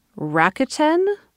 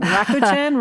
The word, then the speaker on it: Rakuten: And what's funny is the whole ad is people trying to figure out how to pronounce it, going Rakuten, Rakuten, Rakuten. So R-A-K-U-T-E-N is how it's spelled.